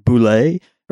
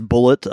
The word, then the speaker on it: Bulleit: You know, quickly, because it, you know, you look at it quickly and it kind of goes like that, but it's spelled B-U-L-L-E-I-T. And there, a few people think there's a misconception that you might be, you're supposed to pronounce it as like boulet or something, but that's, but that's actually boulet crap. The official pronunciation of this is Bullet Bourbon.